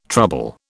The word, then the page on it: trouble: British English